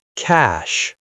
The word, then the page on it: cash: American English